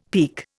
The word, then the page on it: peek: Indian